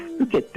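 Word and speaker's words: 深井: このおふけ焼きとはですね、名古屋城内で焼かれてたですね、いわゆる殿様のお庭焼きってものでですね、で、ふけってのは、深いって字に、井戸の井って書いて、これふけって読むんですけど、これはですね、もともとの意味は沼地とか湿地帯のことを指す言葉なんですね。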